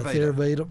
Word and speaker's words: Theravada: Yeah, Theravada.